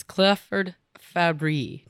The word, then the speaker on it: Clifford Fabbri: This is Clifford Fabbri.